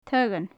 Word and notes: taghan: gaelic

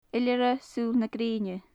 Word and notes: iolaire suil na greine: gaelic

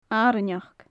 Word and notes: arainneachd: gaelic

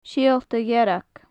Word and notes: siolta dhearg: gaelic

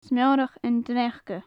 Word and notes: smeorach an t-sneachda: gaelic

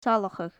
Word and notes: salachadh: gaelic

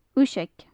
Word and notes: uiseag: gaelic

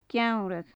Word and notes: Geamhradh: Gaelic

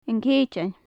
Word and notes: an ceitean: gaelic

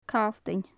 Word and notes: calltainn: gaelic

